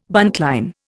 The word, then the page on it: buntline: Indian